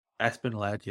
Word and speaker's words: Aspen Ladd: Aspen Lad, yeah.